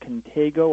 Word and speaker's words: contango: I think it's pronounced contango or contango.